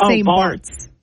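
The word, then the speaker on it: St. Barths: But it's spelled with an H at the end. – Is it? – It's like it's spelt like St. Barth's, but it's St. Bart's. – I don't know. – But it's spelt like Barth.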